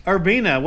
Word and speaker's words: Urbina: Let's talk to somebody who has, Mr. Seth Urbina. What have you been doing? Did I say that right? – Yes, you got Urbina.